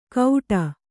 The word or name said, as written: ಗೌಡ